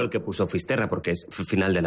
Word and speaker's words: Fisterra: Entonces llegó a Finisterre, ¿se dice así? – Fisterra. – Es como se ponen los gallegos también, en fin.